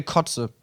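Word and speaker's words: Koze: Aber das neue DJ Cozy Album soll wohl Killer sein. Guter Mann. – Geil. – DJ Kotze könnte man ihn auch aussprechen.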